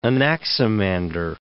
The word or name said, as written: Anaximander